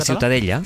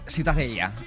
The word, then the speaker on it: Ciutadella: ¿cómo se pronuncia? – La Ciutadella. – Ciutadella. Bueno, pues Ciudadela también en castellano. – Ciudadela, sí. Bueno, pues eso, ha quedado clarísimo. ¿Para qué se meten? – ¿Tú cómo lo pronuncias? – Ciutadella.